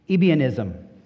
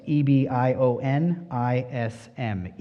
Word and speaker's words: Ebionism: A quick overview of some of the early false teachings and teachers included this the first being Ebionism. I'll spell that for you if you want, if you're taking notes E B I O N I S M, Ebionism.